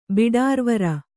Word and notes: ಬಿಡಾರುವಾರ: biḍāruvāra; kannada

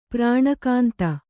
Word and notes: ಪ್ರಾಣಕಾಂತ: prāṇa kānta

